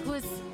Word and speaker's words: Goes: It's called Hoes. That's how you pronounce it. – Kat Goose. – In English, they say Ghost. What's your last name? – My last name is Rodriguez.